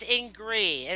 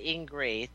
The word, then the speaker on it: Ingrid: By the way, it's Ingrid. It rhymes with angry, so you just say Ingrid. Thank you, Jack. – Okay. Ingrid. – Yeah, I'm not always angry, but it does rhyme with angry. It's just the way it is. So just drop the D on Ingrid.